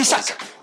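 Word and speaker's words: Isak: ¿Se pronuncia Isaac? – Isaac.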